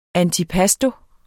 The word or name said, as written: ANTIPASTI